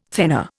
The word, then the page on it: thenar: Indian